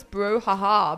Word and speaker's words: brouhaha: Alright, the next one also I think I'm pronouncing it correctly. It's bro or yeah, I think it's bro haha. Bro haha. – Yeah. – Bro ha. I like this one. – Wait. Brouhaha.